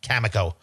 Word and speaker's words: Cameco: Hopefully everybody's happy I finally said that correctly. Not Cameco, as I was corrected and scolded by many of you. Cameco.